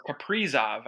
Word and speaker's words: Kaprizov: Kirill Kaprizov, or Kaprizov, I believe is how they say it in Russia, is supposedly coming to the Minnesota Wild.